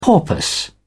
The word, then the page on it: porpoise: British English